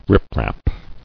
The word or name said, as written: Riprap